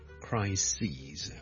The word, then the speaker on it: crises: E-S 上一集讲的最常见的例子就是 crisis， C-R-I-S-I-S 一个危机， a big crisis， a number of crises， okay，留意个发音，串字当然是不同的，但发音是不同的， crisis 和 crises。